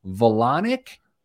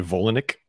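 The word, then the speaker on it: Volonic: Do you think it's pronounced Velonic? It's V-O-L-O-N-I-C. Or is it like Volani? – As a Volanic? – Volanic. That sounds better than Velonic, right? – Yeah. – You just don't want to rhyme anything with colonic.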